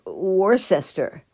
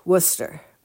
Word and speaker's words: Worcester: It's spelled with an R, W-O-R-S-C-E-S-T-E-R. It looks like Worcester, but it's pronounced Worcester.